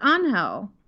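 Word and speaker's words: Ángel: Angel, which in Spanish-speaking cultures is traditionally a male name, pronounced Angel.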